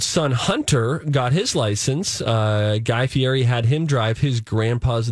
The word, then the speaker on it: Guy Fieri: Guy Fieri.